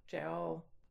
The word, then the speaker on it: gaol: Of course, the British spell it G-A-O-L, and it's jail.